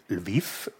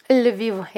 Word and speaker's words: Lviv: Sie sind in, wir haben es schon gesagt und gehört, in der westukrainischen Stadt Lviv geboren und aufgewachsen. Wie spricht man es richtig aus? – Lviv, ja.